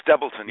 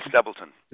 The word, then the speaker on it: Stebleton: I didn't butcher your last name, did I? Stebleton? – No, you got it right. Stebleton, yes, Stebleton. That's right.